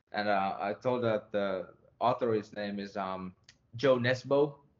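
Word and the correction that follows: Jo Nesbø: And then he goes, oh, it's Jo Nesbo. And I'm like, okay, so I've been pronouncing it completely wrong.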